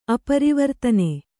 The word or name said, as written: ಅಪರಿವರ್ತನೆ